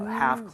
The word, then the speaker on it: half: Half closed. Okay? 자, 여기 L 발음은 하지 않아요. 그렇기 때문에 help라고 하시면 절대 안 됩니다.